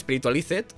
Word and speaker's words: Spiritualized: Se escribe Spiritualized, con Z y con D, pero se pronuncia Spiritualize popularmente, todo el mundo dice, lo llama así.